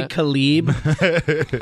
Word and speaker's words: Caleb: And then, it's actually Kalib.